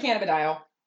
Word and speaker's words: cannabidiol: Eh, CBD cannabidiol.